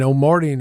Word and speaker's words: Omartian: And Omartian is spelled like O Martian, like a Martian. – Yes, like Martian.